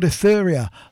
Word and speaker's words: Aethoria: They're called Etheria. That's spelled A-E-T-H-O-R-I-A.